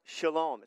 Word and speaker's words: shalom: And peace in the Hebrew is pronounced shalom.